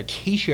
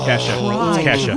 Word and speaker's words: Kesha: Don't give me another Keisha or Keisha. – Kesha.